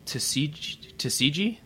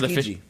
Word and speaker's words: Tsukiji: I believe the brand is called Sugimoto, and it was made in that in the neighborhood Tsukiji. That's how you pronounce it. – Where Tsukiji.